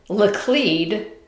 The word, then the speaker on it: Laclede: Another mispronunciation. Laclede should be Lacled.